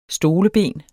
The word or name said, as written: stoleben